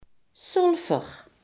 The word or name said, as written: zwavel